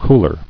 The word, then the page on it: Cooler: cool·er